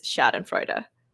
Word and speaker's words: Schadenfreude: In case you enjoy a little, is it Schadenfreude? I want to make sure I pronounce. – Well, okay. I'm an expert based on my Google search of how to pronounce this, but I'm pretty sure it is Schadenfreude.